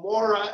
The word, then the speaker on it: Mora: If anybody is listening and they want to find the Steve Mora Marco conspiracy, my name is spelled Mora, M-O-R-A, and then Marco, M-A-R-C-O.